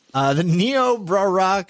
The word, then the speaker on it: Niobrara: Niobrara County.